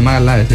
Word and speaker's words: Magalhães: Magallanes, no, Magalhães. Está mal decido, está bien decido.